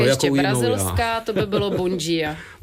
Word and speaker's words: Bon dia: Bon dia. – To bylo super, řekl jste to naprosto správně.